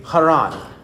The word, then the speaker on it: Haran: And in the original language, the H was a hard H or a guttural H. It would be pronounced more distinctly like a kh, like that, or kh, in that way, Haran.